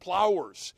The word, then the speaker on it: pliers: Folks, where I'm from, pliers are pronounced plowers.